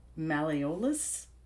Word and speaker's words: malleolus: She broke her tibia and her tibial, I'm going to butcher this, malleolus.